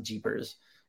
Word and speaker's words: GPRS: It's like, you know, Edge, and then there was a uh, um, GPRS, they pronounce it Jeepers.